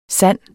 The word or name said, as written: sande